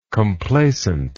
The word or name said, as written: complaisantly